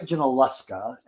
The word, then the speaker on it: Junaluska: But yes, it's Lake Junaluska. And Junaluska.